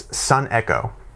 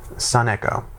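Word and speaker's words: sun echo (συνέχω): What's important to note here is that in the Greek, the word is sun echo. That's how you pronounce it, sun echo.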